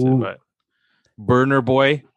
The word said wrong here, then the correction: Burna Boy: Burna Boy. I apologize. – Burna Boy.